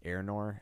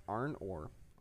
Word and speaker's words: Arnor: And it's actually pronounced Arnor. no. But yeah, it's pronounced Arnor. Arnor.